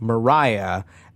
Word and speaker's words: Maria: It's written Maria, like how you would normally write Maria, but for whatever reason is pronounced Mariah, and that is where Mariah Carey gets her name.